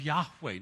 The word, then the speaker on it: Yahweh: First of all, he's called the Spirit of Yahweh. Notice Lord all in capitals. Translates the Hebrew word YHWH, pronounced Yahweh.